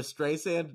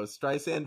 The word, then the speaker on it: Streisand: Barbara Streisand? No. Streisand? Yes.